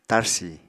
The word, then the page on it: tarsi: Apatani